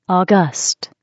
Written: august

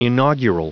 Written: inaugural

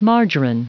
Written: margarine

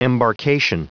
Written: embarkation

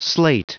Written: slate

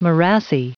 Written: morassy

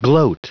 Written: gloat

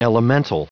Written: elemental

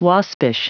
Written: waspish

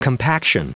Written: compaction

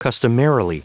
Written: customarily

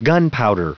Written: gunpowder

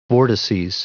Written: vortices

